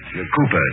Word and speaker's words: Cowper: William Cowper. – Cooper, darling. – Hmm? – It is spelled Cowper, but pronounced Cooper.